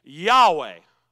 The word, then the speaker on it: Yahweh: So it says YHWH. But the best way we could describe it or pronounce it is Yahweh.